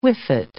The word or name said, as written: whiffet